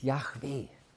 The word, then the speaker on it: Yahweh: J-H-W-H. Kann man im Prinzip gar nicht aussprechen. J-H-W-H. Nur Konsonanten. Wir sagen, das Wort hieß wahrscheinlich Yahweh, weil es ein Name war, der kaum gebraucht worden ist, weil man sagte, das ist so heilig, wir wollen ihn kaum aussprechen.